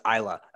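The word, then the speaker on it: Islay: Is it Isla? – Oh, yes. – I did it. – I almost thought, in my brain, I still said it wrong. – I did too. And I was like, don't do it, man. It's Isla.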